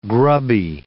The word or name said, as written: grubby